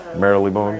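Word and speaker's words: Marylebone: That's how you say it? Merrily Bone, right? Merrily Bone. Merrily Bone, yeah.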